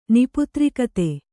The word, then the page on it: ನಿಪುತ್ರಿಕತೆ: kannada